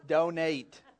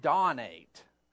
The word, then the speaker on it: donate: It says donate. But if I'd have done it D-O-N, then you'd have pronounced it dawn-ate. And you'd have gone, what does dawn-ate mean? It's donate. And that's the title of today's message.